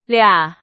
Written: 俩

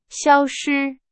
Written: 消失

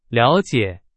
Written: 了解

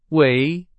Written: 围

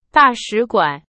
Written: 大使馆

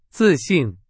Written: 自信

